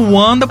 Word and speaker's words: Wanda: Eu tô dizendo Wanda porque a pronúncia oficial é Wanda, mas podemos chamar ela de Wandinha.